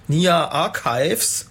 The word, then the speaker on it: Nia Archives: Ja, das war Vertical von Nia Archives. Habe ich das richtig ausgesprochen? – Hast du fein ausgesprochen.